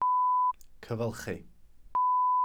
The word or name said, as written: cyfylchi